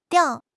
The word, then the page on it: 吊: HSK6